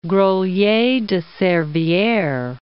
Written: Grolier de Servieres

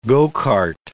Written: go-kart

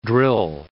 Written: drill

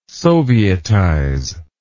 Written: sovietize

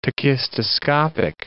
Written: tachistoscopic

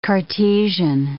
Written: Cartesian

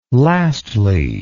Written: lastly

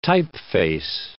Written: typeface

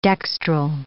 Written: dextral

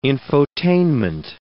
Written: infotainment

